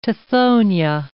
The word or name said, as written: tithonia